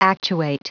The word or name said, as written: actuate